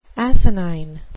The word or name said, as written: asinine